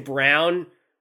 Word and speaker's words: Braun: does it bother you that Christian Brown is pronounced Brown, but the name is so clearly spelled Braun? Like, yeah. – I've never seen a Braun name, like, it's spelled like Braun, but it's said Brown.